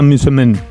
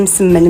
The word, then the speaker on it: msemen: El ergaif marroquí, por cierto, recibe también el nombre de misemen. O algo así. Una palabra extraña. Es una M líquida, misemen, ¿vale? – Bueno, una M líquida.